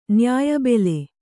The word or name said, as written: ನ್ಯಾಯಬೆಲೆ